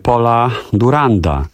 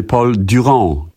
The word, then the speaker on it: Paul Durand: Proszę Państwa, ten piękny numer to jest francuski numer Paula Duranda, i czy Paul Durand, tak się to powinno wymawiać, ale spalszczam to.